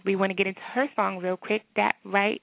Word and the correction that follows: That's Right: I said That Right. It's called That's Right.